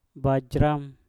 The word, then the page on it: bajram: mpur